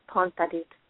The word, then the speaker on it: Phonpadith: It's Molly Phonpadith.